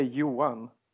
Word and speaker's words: Johan: Oh, it's very soft in Swedish. It's you say Johan. It's almost like a girl's name, I think.